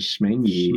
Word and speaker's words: Schmengie: Schmengie, Correct. Schmengie, Schmengie. It's spelled Schmengie. H M E N G I E. It has a C in it.